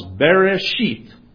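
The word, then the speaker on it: Bereshit (בראשית): The word beginning in the Hebrew is actually the word what would be pronounced Bereshit.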